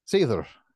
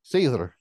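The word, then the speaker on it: seiðr: Is Seor how you say it? – Seether. – Seether? – Seether, yep.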